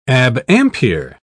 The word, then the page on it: abampere: British English